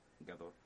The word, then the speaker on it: Gadot: Entonces ya tuvieron que cambiarla a Gal Gadot, que se pronuncia Gadot. – ¿Cómo? ¿Gadot? – Gadot, así como se llama.